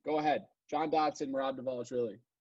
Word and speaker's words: Dvalishvili: The D is actually pronounced. It's Mehrab Dvalishvili. Wow.